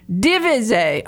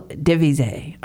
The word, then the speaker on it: DeVise: I don't know if you're French, but I have French people on, with French names, and I pronounce it the French way, and then that turned out to be wrong. So I love it. It's much more elegant. Daniel DeVise.